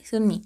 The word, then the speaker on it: Sunmi: Tail de Sunmi, sí se dice así, Sunmi.